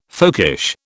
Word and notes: folkish: British English

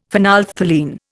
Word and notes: phenolphthalein: Indian

